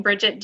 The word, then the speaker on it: Bridget: Or you can find me on Facebook or Instagram if you just search my name, Bridget Duhame. That's B-R-I-D-G-E-T. And last name is spelled D-U-H-A-I-M-E.